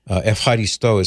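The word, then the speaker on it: Evharisto (ευχαριστώ): Evharisto is how it's pronounced. I now know from my travels to Greece, I've learned this, that Evharisto is the way, at least the way an American would pronounce it in modern Greek.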